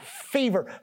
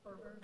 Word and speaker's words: fervor: Serve God with white hot fever. Fever or fever? How do you say that? Fervor.